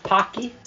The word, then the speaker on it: Paqui: How do I pronounce it? – Yeah, tell them the name of the chip. – The Pocky. P-A-Q-U-I. Pocky one chip challenge.